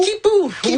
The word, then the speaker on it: Quipu: Kipu, oui. – Ça s'écrit Q-U-I-P-U.